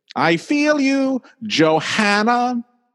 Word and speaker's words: Johanna: Johanna. And it's like Joanna. That's how it says. I know there's an H in it, but we're saying it Joanna.